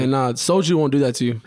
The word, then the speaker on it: soju: I wonder if you're even pronouncing it right. – Soju. – Soju. – You know, it sounds like I'm saying soldier.